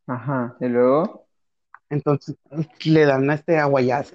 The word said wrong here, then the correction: ayahuasca: aguayas, ¿cómo se dice, güey? Ayahuasca. Ayahuasca, güey y dice que cambió totalmente su perspectiva de todo, que para él una piedra tiene vida, güey.